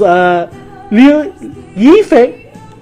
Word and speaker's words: Liu Yifei: Her name is Liu Yifei. Nope. That's how you pronounce it.